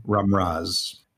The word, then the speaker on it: Ramraz: Last name, Ramraz.